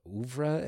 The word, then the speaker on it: oeuvre: Whereas Superbad, also from Judd's, you know, oeuvre, am I pronouncing it right? I'm not smart enough. Yes.